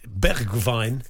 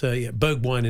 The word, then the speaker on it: Bergwijn: Likewise, well done to Tottenham's new signing, Stephen Bergwijn, because they asked him how to pronounce his name. And he said it's Bergwijn. It's a bit like Greta Thunberg in Dutch. And he said, but it's Bergwijn in English.